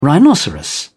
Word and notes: rhinoceros: British English

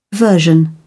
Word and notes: version: British English

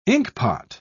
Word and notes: inkpot: British English

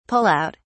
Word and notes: pull out: British English

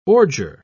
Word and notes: ordure: British English

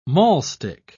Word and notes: maulstick: British English

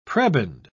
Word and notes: prebend: British English

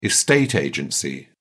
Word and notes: estate agency: British English

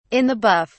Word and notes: in the buff: British English

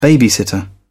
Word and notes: babysitter: British English

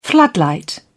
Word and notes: floodlight: British English

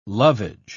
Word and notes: lovage: British English

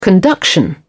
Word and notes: conduction: British English

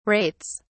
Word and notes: rates: British English

